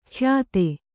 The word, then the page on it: ಕ್ಯಾತೆ: kyāte